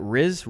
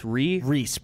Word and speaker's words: Rhys: All right, this one is from, I never know how to pronounce this name, R-H-Y-S. Is that Riz? – Reese, probably.